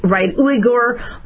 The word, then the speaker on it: Uyghur: if I want to pronounce it right, Uyghur minority in the Xinjiang province.